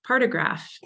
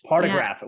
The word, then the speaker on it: partograph: pectograph. Is that right? Am I saying that right? – Partograph. – Partograph.